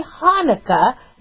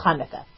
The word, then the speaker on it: Hanukkah: You could say Hanukkah, make it a soft H if you don't want to spit on anybody, but the correct pronunciation is Hanukkah.